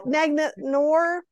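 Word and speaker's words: Deglet Noor: I think it's Deglet Noir. – Deglet Noir. – I don't think it's noir like film noir.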